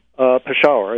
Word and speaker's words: Peshawar: Peshawar, I guess is the correct pronunciation,